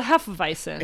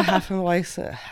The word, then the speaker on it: Hefeweizen: It's a Hefeweizen.